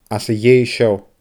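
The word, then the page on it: às a dhèidh seo: Scottish Gaelic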